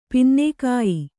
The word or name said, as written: ಪಿನ್ನೇಕಾಯಿ